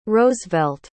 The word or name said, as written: rosevelt